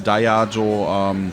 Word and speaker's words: Diageo: Spricht man tatsächlich auch verschieden aus. Okay. Gerade wenn du nachher nach UK rüber gehst. Die haben zum Teil Diageo. Es ist einerseits Latein, andererseits Griechisch. Das heißt Dia, der Tag, Geo, die Welt.